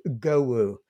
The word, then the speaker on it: Gewu: Technically it probably should be pronounced Gewu. – Okay. – Which is that E is kind of more like an R.